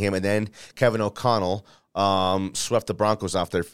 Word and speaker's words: O'Connell: Daboll took over and O'Connell, did I say it right or did I say it wrong again? – You said it right.